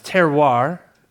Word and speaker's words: terroir: So it's pronounced terroir. It's a French word.